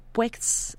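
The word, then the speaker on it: PUEDJS: el pues, que es el programa PUEX, así se dice. Es un poco complicado eh, su pronunciación, pero es el Programa Universitario de Estudios sobre Democracia, Justicia y Sociedad.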